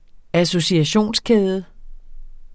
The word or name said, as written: associationskæde